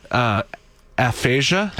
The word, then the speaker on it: aphasia: He says aphasia.